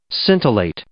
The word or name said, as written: scintillate